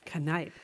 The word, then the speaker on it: Kneipp: We would also like to thank a new sponsor, Knipe. – Knipe. Is that how you pronounce it?